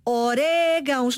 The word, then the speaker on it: orégãos: Orégãos, acento no E. Porquê? Porque se eu não tiver acento, naturalmente as palavras terminadas em ão gostam de receber a sílaba tónica. Irmão. Coração. – Neste caso seria orgão. – Pois, não, se eu não colocar o acento sobre a vogal, a tonicidade recai no ão.